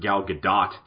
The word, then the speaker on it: Gal Gadot: We got to see Gal Gadot back I'm actually being told it's pronounced Gal Gadot by the internet these days.